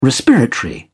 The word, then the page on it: respiratory: British English